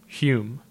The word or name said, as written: Hume